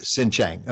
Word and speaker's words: Xinjiang: I don't know how to pronounce the region. The region? – Xinjiang. Yes. – Xinjiang. Okay, thank you.